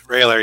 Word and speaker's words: Roehler: It's Railer, yeah.